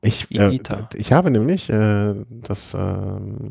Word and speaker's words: Higuita: Ich weiß nicht, wie man es, Higuita, spricht man es richtig Higuita, aus? Higuita, ja. – Higuita. Also ist das U stimmlos? Higuita? – Das I, das H spricht man nicht mit Higuita.